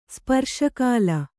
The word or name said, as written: ಸ್ಪರ್ಶಕಾಲ